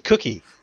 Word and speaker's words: Gookie: Gookie it is. Okay, from now on. So it rhymes with cookie.